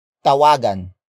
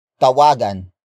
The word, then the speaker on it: tawagan: Take note that tawagin should not be confused with tawagan. Tawagan.